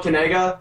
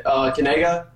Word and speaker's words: Kanaga: So this is how you pronounce it. It's Noah Kanaga. You say it Kanaga, like that.